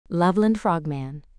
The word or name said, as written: Loveland Frogman